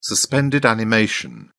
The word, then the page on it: suspended animation: British English